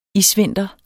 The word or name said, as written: isvinter